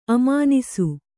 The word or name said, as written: ಅಮಾನಿಸು